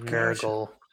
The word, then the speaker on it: Nurgle: Did you say the son of Nurgal? – Yeah. – Yeah. – That's how it's pronounced in the movie. Son of Nurgle.